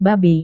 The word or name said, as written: babi